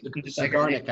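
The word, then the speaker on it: Zeigarnik: Zagarnik, I believe, is the correct Russian pronunciation.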